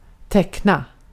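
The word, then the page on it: teckna: Swedish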